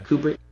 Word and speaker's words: Kubrick: So it's like they were saying that maybe Kubrick. Am I saying that right? – Yeah. – Kubrick.